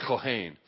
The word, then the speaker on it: Kohen: Kohen, my last name, in Hebrew is pronounced Kohen.